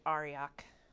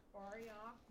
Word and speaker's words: Arioch: When Arik, how do you pronounce that? – I think I just called it Arioch. – Arioch,